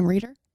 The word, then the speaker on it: Reider: That's how you'd pronounce the last name, Reider. – Reider? – Reider. – And we'll ask her. – R-E-I-D-E-R.